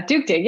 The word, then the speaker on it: Duktig: So, why don't you tell a little bit about, I'm not gonna be able to pronounce it. Duktig. – Whoa. Yeah. Duke did. Yeah. Okay. Good. Very Swedish.